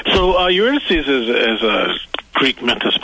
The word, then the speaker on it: Eurydice: So who's directing, is this Eurydice? Am I saying that right? – Eurydice. – See, I knew Eurydice. I was to say it wrong.